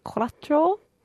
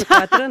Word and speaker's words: cuatro: Okay, in 5-6 minutes' time, we are going to hear put the cuatro. I don't think I said that right. – Cuatro, no, it's good.